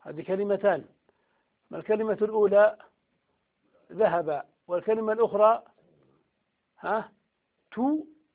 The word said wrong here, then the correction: تاء: ولا تاء التان ولا تاء الفاعل؟ تاء. أنا قلت تو، نقول تاء.